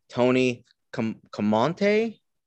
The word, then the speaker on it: Camonte: Tony Camonte. Am I saying that right? – Yeah, I think so.